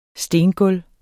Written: stengulv

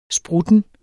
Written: sprutten

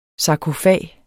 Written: SARKOFAGEN

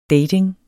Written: dating

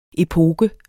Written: epoke

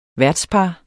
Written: værtspar

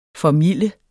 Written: formilde

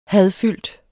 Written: hadfyldt

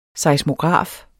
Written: seismograf

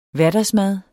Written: hverdagsmad

